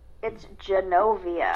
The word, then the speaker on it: Genovia: It's Wachovia? Did I say it wrong again? – No, it's Genovia.